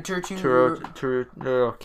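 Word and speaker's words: Turturro: Three, John Turto. Turturo. – Can't say his name.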